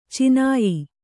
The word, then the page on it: ಚುನಾಯಿ: cunāyi